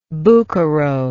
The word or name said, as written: bucchero